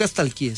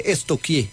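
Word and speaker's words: stalkeé: Porque no se dice nunca estalqueé, se dice estoqueé. – ¿Estoqueé? Sí. – Pregúntele a la profe, verá. A mí una vez estamos estoqueando. – Sí, estoqueando. Yo, pero se escribe estalquear. Es estoquear.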